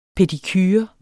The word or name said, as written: pedicure